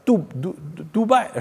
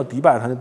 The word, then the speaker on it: 杜拜: 那驾照上写着呢，那驾照我也看不太懂，但驾照上写着就是什么呢？是这个迪拜啊，杜拜是不是？他那个他的发音正确发音叫什么？就杜拜，杜拜啊，杜拜，我们一般叫迪拜，他那杜拜，你看我杜拜，我说哦厉害，然后他我跟我老婆不还说要想那个移民去这个迪拜嘛，不还说挺好。